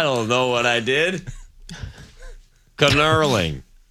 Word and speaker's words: knurling: 800 people have let me know it's pronounced knurling. The K is silent. Sorry. – Oh. – It's not knurling.